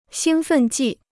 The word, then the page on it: 兴奋剂: xīng fèn jì